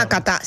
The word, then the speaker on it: Nakata: Ricordiamo magari una piccola parentesi calcistica che nella Serie A italiana hanno giocato, lo pronuncio bene, Hidetoshi Nakata, lo pronuncio bene? Nakata, te lo ricordo? – Nakata, sì, Nakata, famosissimo.